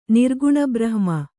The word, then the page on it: ನಿರ್ಗುಣಬ್ರಹ್ಮ: Kannada